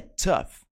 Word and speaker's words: tough: É tough. Tough. Repare ali como essa palavra é escrita e como ela é pronunciada. Uma palavra difícil de você pronunciar certo, só lendo.